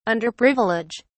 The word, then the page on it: underprivilege: British English